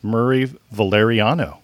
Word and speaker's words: Murray Valeriano: So please welcome to the podcast, Murray Valeriano.